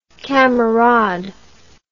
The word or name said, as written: kamerad